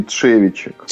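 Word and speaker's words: Trzewiczek: как правильно фамилия-то произносится? – Игнаций Тшевичек. – Тшевичек, да-да-да.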